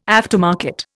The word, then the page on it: aftermarket: Indian